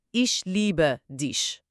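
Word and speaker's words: Ich liebe dich: تلفظش هست ایش لیبه دیش.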